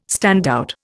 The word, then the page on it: standout: Indian